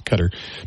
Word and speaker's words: Qatar: Item number two, about the airplane from Qatar or Qatar, depending on how you want to say it. I think it's technically pronounced Qatar.